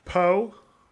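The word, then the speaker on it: Pokémon: So, Google Pokemon... pronunciation, it's Pokemon.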